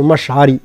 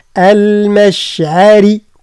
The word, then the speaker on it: المشعر: فإذا أفضتم يعني نفخم كان الضاد، أفضتم هكذا من عرفات كان الراك كذلك، فاذكروا وليست فاذكروا، فاذكروا كان الراء هي المفخمة، ثم عند المشعري مش كما نطقها في الدارجة سيدة قولوا مشعري، لا المشعر، لا المشعري كلها مرقة حتى الراء لأنها مكسورة، عند المشعري الحرام.